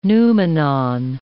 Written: noumenon

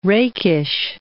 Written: rakish

